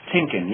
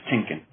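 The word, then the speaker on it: Tienken: It's pronounced Tinkin, yes, Chris Tinkin.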